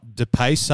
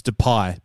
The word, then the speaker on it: Depay: It's not DePace up front for Lyon, it's Depay.